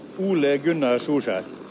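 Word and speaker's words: Ole Gunnar Solskjær: Ole Gunnar Solskjaer.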